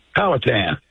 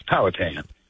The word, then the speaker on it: Powhatan: B-O-W-H-A-T-A-N. Powhatan? – Powhatan, like the Indians, Powhatan. – Oh, well, of course. Sorry about that. W-J-F-N, how are you, sir? – I'm just fine, Mark.